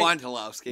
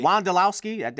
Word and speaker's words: Wondolowski: Wondolowski. – Wondolowski.